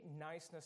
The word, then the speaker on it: Knysna: As you see there, it's a silent K, Knysna, South Africa.